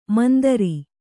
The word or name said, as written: ಮಂದರ